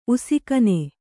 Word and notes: ಉಷಾಗಾನ: uṣāgāna

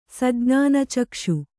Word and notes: ಸಜ್ಞಾನಚಕ್ಷು: sajñāna cakṣu

